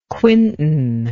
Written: quintan

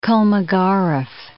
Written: Kolmogorov

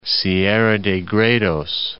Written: Sierra de Gredos

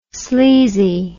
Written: sleezy

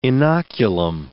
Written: inoculum

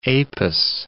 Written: Apis

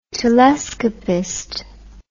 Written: telescopist

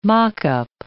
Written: mock-up

